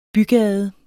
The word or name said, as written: bygade